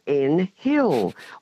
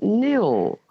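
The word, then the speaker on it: Nhill: And we often get called N Hill. Well, it's not. The the H is silent and it's N-H-I-L-L, of course, and it's pronounced Nil.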